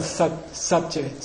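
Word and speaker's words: sa bcad: By the way, they're called sabje. Say sabje. – Sabje. – Sabje. Sabje. For those of you who care, it's S-A-B-C-A-D. Okay? So the prefix letter is pronounced.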